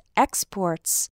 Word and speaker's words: export: For example, coffee and soybeans are the country's biggest exports. That means the country sends coffee and soybeans to other countries. Now, when we stress the second syllable of the word, export and import, that refers to the action.